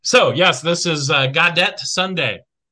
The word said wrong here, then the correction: Gaudete: I'm Googling it. Guadete pronunciation. Ready? Here we go. I'm even picking the guy who speaks French. Go! Gaudete.